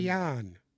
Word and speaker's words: Siemian: Proper pronunciation, Siemian. He did not have a very nice Simeon. – Game. – Simeon. Me-on. Simeon.